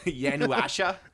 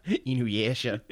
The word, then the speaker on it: Inuyasha: Yanuasha? Is that Yanuasha? – Inuyasha.